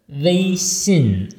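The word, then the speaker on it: Weixin (微信): Diesmal war die Geste auch falsch. Also Weixin. Einmal flach, einmal unten. – Weixin.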